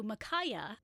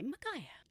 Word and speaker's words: Micajah: H-A-R-P. William changed his name to Micaiah, and it is spelled M-I-C-A-J-A-H.